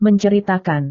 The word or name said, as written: menceritakan